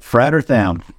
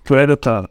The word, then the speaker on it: Frater Tham: Frater Tham. – Frater Tham.